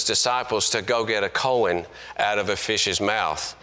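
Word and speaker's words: Cohen: I mean, that's C-O-H-E-N, that's how you spell it. No, it's coin, you know. So I've tried to learn to say coin instead of what it should be said, you know, coin.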